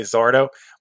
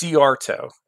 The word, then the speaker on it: Szyarto: it's actually, if anyone looks at my name, they all say the same way, Zarto, but it's Ciarto. You can't even look at it when you say it.